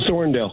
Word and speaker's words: Thorndale: No, Thorndale. – It is Thorndale, okay.